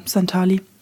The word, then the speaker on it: Santali: Oder wie wird es ausgesprochen? – Santali. – Santali, genau. Mhm.